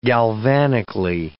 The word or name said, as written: galvanically